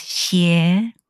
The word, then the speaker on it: 鞋: Also, the pronunciation of shoes is xie, right? 鞋子. – Mm. – And also in Chinese, the word evil is pronounced xie as well.